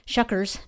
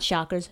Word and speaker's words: Shuckers: Shuckers, not Shockers, Shuckers.